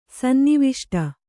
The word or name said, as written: ಸನ್ನಿವಿಷ್ಟ